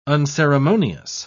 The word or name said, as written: unceremonious